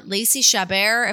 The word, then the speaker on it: Lacey Chabert: They use her, Lacey Chabert, if I'm even saying her name right.